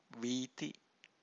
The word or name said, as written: vithi